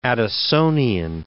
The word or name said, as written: Addisonian